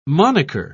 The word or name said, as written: moniker